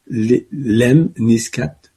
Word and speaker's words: lemniscate: Et elle permet justement de se rouvrir grâce au 4, L'em niscat peut-être je le dis mal, mais je vous le dis, ça s'écrit L-E-M-N-I-S-C-A-T-E, euh, e, c'est ça. Lemnisca, effectivement.